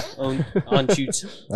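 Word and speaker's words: Anschütz: So what's the actual pronunciation? Do we know? – Anschutz. – Anschutz. – Anschutz? – No one actually When you hear Jochen say it, that's the only way.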